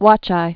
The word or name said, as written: watcheye